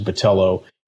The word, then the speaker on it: Botelho: And it is, like you said, the pronunciation, Americanized pronunciation of it is Botelho, and it's spelled with an L and an H before the last letter O.